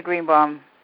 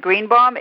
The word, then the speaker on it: Greenbaum: And then it's also under my name, Tina Greenbaum. – It's spelled out for the listening audience, not everyone. – It's T-I-N-A, that's my first name. And Greenbaum is G-R-E-E-N, like the color. B as in boy, A-U-M as in Mary.